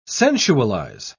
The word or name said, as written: sensualize